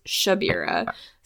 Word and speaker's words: Shabira: It's Shabira. – Is it a sh sound? – That's what Prude is saying.